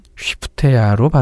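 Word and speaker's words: 쉬프테야 (שְׁפַטְיָה): 다섯째 아들은 스바디아입니다. 히브리어는 쉬프테야로 발음되는데 여호와가 심판했다는 뜻입니다.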